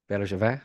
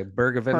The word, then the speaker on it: Bergevin: And you make a good point Bergevin? I can't pronounce it nearly as well as you. Is that close? Bergevin? – Pretty close.